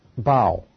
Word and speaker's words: bough: He says about Joseph, he said, Joseph is a fruitful bough. Did I say that right? Bough? Okay, good, good.